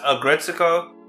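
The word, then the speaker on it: Aggretsuko: Agretsuko, or Agret, how do you pronounce that? – Oh, Agretsuko Season 5. It's pronounced Agretsuko.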